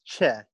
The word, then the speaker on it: Che: Again, my name is Che. You spell that C-H-E.